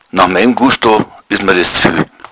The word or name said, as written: gusto